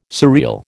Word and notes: surreal: American English

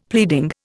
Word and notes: pleading: Indian

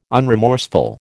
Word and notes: unremorseful: American English

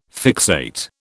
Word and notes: fixate: British English

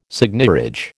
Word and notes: seigniorage: American English

